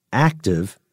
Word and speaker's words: ACTIV: DARPA is aiming to build a fleet of unmanned ships called Anti-Submarine Warfare Continuous Trail Unmanned Vessels, to be pronounced ACTIV for short.